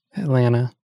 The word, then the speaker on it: Atlanta: You don't pronounce the second T, Atlanta.